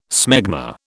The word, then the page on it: smegma: British English